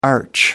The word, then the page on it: arch: American English